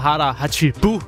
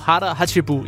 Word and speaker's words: hara hachibu: Det hedder hara hachibu på japansk. – Og det er du sikker på, at ikigai udtale? Og hara hachibu. Ja, ja, fuldstændig.